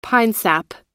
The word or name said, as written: pinesap